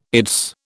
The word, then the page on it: its: British English